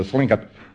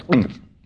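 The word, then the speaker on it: Tlingit: I was talking to the Thlinkup witch doctor one time. As a matter of fact, I didn't pronounce that properly. I'm sorry, it's Thlinkup.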